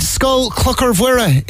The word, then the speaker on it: Scoil Chlochair Mhuire: Scoil Chlochair Mhuire in Carrigtwohill, I hear. – Yes. Scoil Chlochair Mhuire in Carrigtwohill.